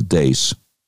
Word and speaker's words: Deace: And the great Steve Dace, it's spelled D-E-A-C-E. So it looks like Dees, but he pronounces Dace.